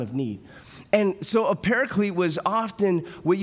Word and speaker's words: paraclete: So parakletos, which we pronounce as paraclete in the English, is one who you call out to come alongside you, to come to your side, especially in times of need, right?